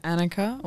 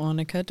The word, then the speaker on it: Anneka: Hi, I'm Annika, or Annika, depending on your pronunciation.